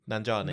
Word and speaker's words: Nanjiani: Kamal Nanjiani.